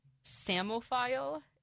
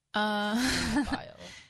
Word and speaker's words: psammophile: Spelling Bee with the word, I'm going to try to say it correctly, Samophile. It's spelled P-S-A-M-M-O-P-H-I-L-E. – Samophile.